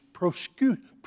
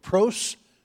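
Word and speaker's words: prosyuske (προσευχή): And then I discovered that the number one word used for prayer in the New Testament is a Greek word that is pronounced in English, prosyuske. Three syllables, prosyuske.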